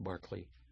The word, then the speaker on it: Berkeley: I did the couple pieces by Lennox Barkley. That's Berkeley, pronounced Barkley.